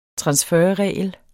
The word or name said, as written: transferregel